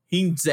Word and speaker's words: Heinze: But this guy, Gabriel Heinze, and I don't really know how to pronounce it, but it looks like Heinz, but it's more like a Heinze in their Spanish language.